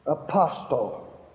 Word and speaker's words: apostle: Apostle. Well, it's not pronounced that way. The T is silent, and you pronounce it apostle.